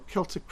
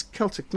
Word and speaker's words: Celtic: When I first started going to Ireland and I spoke of Celtic, no, we must correct you. It's Celtic, not Celtic. But it's spelled with a C.